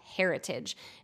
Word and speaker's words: Hairtage: And she has recently, just in the past year or so, created a line called Heritage. And it's spelled H-A-I-R-T-A-G-E.